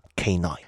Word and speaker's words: K9: 但是这样吧，你把这两个当成两个音节去读出来，是不是就是 K9？ – K9。 – 有一个词 CANINE，这个词就是狗的意思。它其实是犬类的通称。咱们说 dog 是狗，那如果是犬类它叫什么呢？ K9。